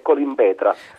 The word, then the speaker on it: Colimbetra: Buongiorno, è Colimbetra. – Allora, comunque, trattandosi di un nome antico, sa benissimo che ci sono tante teorie, quindi vanno bene tutte.